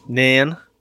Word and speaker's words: Nairn: Nairn.